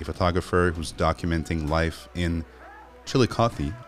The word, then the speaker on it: Chillicothe: And yeah, you nailed the pronunciation of Chillicothe just perfectly.